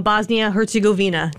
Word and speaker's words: Bosnia-Herzegovina: And they're taking on Bosnia-Herzegovina.